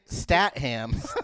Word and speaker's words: Statham: How can you mispronounce something? – It's Jason Statham.